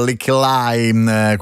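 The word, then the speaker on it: Lykke Li: con la collaborazione anche di Licky Lick e poi a Licky Lai, perché la pronuncia british sarebbe Licky Lai,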